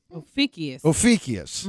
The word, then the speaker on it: Ophiuchus: Ophiuchus.